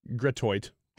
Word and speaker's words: Gratiot: How do you say that street? G-R-A-T-I-O-T. – Gratoit.